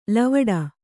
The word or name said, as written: ಲವಡ